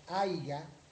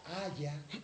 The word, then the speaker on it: haya: La más frecuente es que decimos aiga o decimos hubieron. Por ejemplo, hubieron no se dice, se dice hubo. Entonces diga hubo y no hubieron. Diga haya y no haiga.